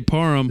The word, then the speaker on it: Parham: I'm looking at Dylan Perham. I think that's Perham, right? That's how you say it. – I would say Parham. – Is it Parham? Parham.